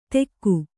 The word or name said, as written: ತೆಕ್ಕು